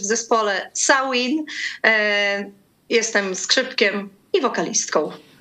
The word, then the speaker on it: Samhain: Z Markiem, z ojcem Patrycji i Wiktora, dodajmy, bo my założyliśmy jako małżeństwo zespół Samhain, który czyta się Sawin.